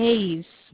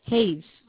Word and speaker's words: Hayes: Hayes. Hayes.